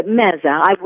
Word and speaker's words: meze: Well, I know that one way that Middle Eastern cuisine varies from American is the lovely habit of doing, I hope I'm saying it correctly, meze. Or do you say meza? – Yeah, meza. We would say in Arabic, we call it meza, but there's nothing wrong with saying meze either.